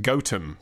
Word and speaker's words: Gotham: However, he says, I soon found out that it is pronounced Gotham by the locals, and I got disapproving looks when pronouncing it a la Bruce Wayne.